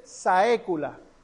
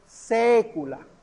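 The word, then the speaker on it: saecula: La AE es una letra que nosotros no tenemos. La A unida a la E es una E abierta. No se dice saécula, se dice sécula.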